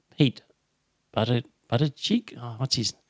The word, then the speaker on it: Buttigieg: What's his – Buttigieg. – Buddha. What is it? – Buttigieg. – Beijing. No, it's pronounced different to that.